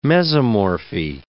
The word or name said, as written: mesomorphy